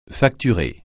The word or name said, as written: facturer